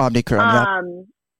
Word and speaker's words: Omicron: I believe it was the newest variant, the Omicron. Omicron, couldn't tell you how to say it. – Omicron, yeah.